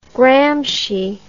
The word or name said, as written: Gramsci